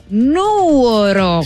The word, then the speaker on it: Nuoro: Nuoro, si con l'accento sulla U.